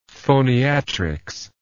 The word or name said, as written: phoniatrics